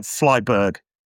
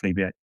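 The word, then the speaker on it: Flyvbjerg: It looks like Flyberg, if you were to write it, but he's Danish. And I'm told by my Danish chums it's pronounced Flubier.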